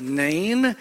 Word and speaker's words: Nain: We would, English letters, we would spell it N-E-I-N. In the Bible, in English letters, it's N-A-I-N, and you may call it Nain. Or if you like to separate vowel sounds from diphthongs, you may say Nain, which is probably how the Hebrews would pronounce it.